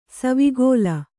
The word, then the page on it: ಸವಿಗೋಲ: Kannada